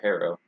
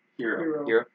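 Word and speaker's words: Herro: Tyler Hero and is it Hero or Harrow? – Hero.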